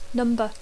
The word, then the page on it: number: English